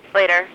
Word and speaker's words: Sleater: Is it Sleater or Slater? – Slater.